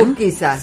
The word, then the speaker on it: Egusquiza: Y nos escribió Víctor desde, a ver si lo digo bien, Egusquiza. – Sí. Ah, muy bien. – Egusquiza, sí.